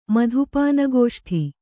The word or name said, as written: ಮಧುಪಾನಗೋಷ್ಠಿ